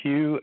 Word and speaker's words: Hugh: Yes, my name is Hugh, H-U-G-H. Last name is Traulson. It's spelled T like Tom, R-A-U-L-S-E-N like Nancy.